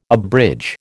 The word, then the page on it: abridge: American English